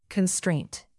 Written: constraint

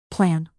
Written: plan

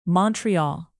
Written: montreal

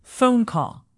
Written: phone call

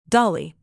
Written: dolly

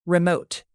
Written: remote

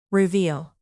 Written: reveal

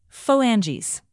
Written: phalanges

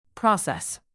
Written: process

